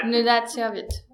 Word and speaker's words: Nunatsiavut: The Nunatsiavut? – Nunatsiavut, okay.